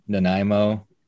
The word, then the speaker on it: Nanaimo: So you got your Nanaimo bars. I wrote down the pronunciation, actually. – You nailed it.